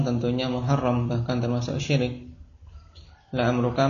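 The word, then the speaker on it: la amruka (لَعَمْرُكَ): Dan kekhususan ketika jadikan kosam ini tidak dibaca kecuali dengan fathah. la amruka.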